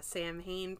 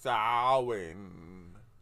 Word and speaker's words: Samhain: It's for a unmasked Samhain pop from Trick or Treat and a DVD copy of WNUF Halloween Special. – It's pronounced Samhain.